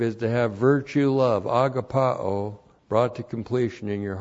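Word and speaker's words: agapaō: Agapao. A-G-A-P-A long O. Agapao.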